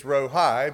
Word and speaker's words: Rohi: Rohi is simply spelled R-O-H-I. Now, if you would just read it in English, you would think it's Rohi, but it's Rohi, Jehovah Rohi.